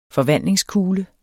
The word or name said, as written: forvandlingskugle